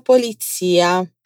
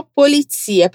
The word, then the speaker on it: polizia: Allora vai dai carabinieri o dalla polizia. Agora fala para mim só polizia, pronunciando a letra Z como se tivesse um TS ali.